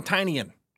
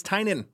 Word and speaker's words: Tynion: I'm sick of people saying Tinian. It's not Tinian. He, you just heard it. It's Tynion. Let's play it again. James Tynion the fourth.